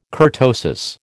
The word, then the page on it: kurtosis: American English